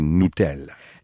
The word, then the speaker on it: Nutella: Comme ma responsabilité de parent, ce serait de lui faire comprendre que la bonne prononciation, c'est Nutella.